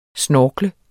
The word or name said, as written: snorkle